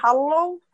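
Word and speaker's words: halló: You can either say hallo or go on by in. – Sie kann Hallo sagen. Die betonen das ein bisschen anders als wir. Also eher die Betonung auf dem L.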